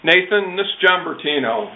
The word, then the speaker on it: Bertino: His last name, first name is John, common spelling, last name is Bertino. B, E as in Edward, R, T as in Tony, I, N as in Nancy, O.